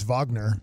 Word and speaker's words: Wagner: You just going off of Franz Wagner for your answer to pronunciation? – That's obvious. – Yeah, I mean, that's who Orlando, that's how you pronounce his name. Mm-hmm. – And so you've extrapolated that we can go ahead and Wagner is Wagner.